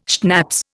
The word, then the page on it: schnapps: Indian